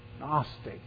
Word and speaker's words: Gnostic: Adam Clark's commentary, he said, they were probably a sect of Gnostics. The word Gnostic starts with a silent G. Like the word knowledge starts with a silent K.